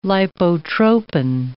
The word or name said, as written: lipotropin